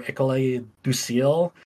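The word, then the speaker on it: École du Ciel: I believe it's pronounced Ecole or Ecole du Ciel.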